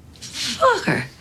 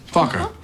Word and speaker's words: Focker: How do you pronounce it? – Oh, just like it's spelled. F-O-C-K-E-R. – Fokker. – Fokker.